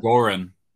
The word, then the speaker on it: Goran: Did I say his name right? No. It's the first. – That's the first time I've said it out loud. What'd you say, Gordon? Goran. Goran Dra- Dragic.